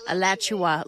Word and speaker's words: Alachua: Alachua, is that how you say it? – Alachua, like Joshua, but Alachua. Alachua.